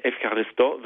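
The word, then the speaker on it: Evcharisto (ευχαριστώ): Das ist ein bisschen anders ausgesprochen. Geschrieben wird es Eucharisto. Äh, Evcharisto wird es ausgesprochen.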